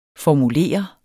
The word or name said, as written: formulere